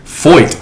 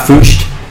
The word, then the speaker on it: Foyt: We're talking Sean Foyt. – I heard somebody say it. – I was like, that's how you say it. – Not Foocht.